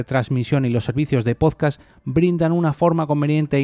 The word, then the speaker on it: podcast: Un podcast del inglés podcast, aquí ojo que empieza con la palabra un podcast con acento en la O,